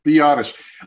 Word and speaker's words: Biadasz: Biadasz.